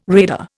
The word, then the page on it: radar: Indian